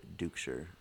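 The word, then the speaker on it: Duchscher: So it's a D-U-C-H-S-C-H-E-R, Jacob, normal spelling with a C, Jacob Dukeshire on Twitter. – And my Twitter is at IamKristen1. And Kristen is spelled K-R-I-S-T-Y-N.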